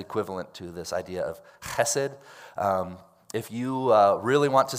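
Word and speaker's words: chesed (חֶסֶד): The word in Hebrew is chesed. I want you all to say that with me and really pronounce the ch in the back of your throat. Ready, everyone?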